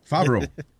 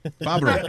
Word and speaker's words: Favreau: I can never pronounce his last name. – Favreau. – Sí, Fabro, oh. es fácil, como Abro, Cierro. Fabro.